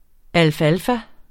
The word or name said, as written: alfalfa